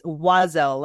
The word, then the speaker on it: Wazelle: Do I say said it? Ozell? It was very close. It's Wazelle, like W-A-Z-E-L-L-E.